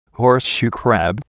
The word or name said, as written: horseshoe crab